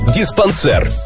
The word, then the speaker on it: диспансер: Диспансер или диспансер? Подумайте несколько секунд. Слово французское, а там ударение падает на последний слог. Поэтому правильно будет «диспансер».